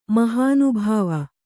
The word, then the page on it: ಮಹಾನುಭಾವ: mahānubhāva